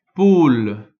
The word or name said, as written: poule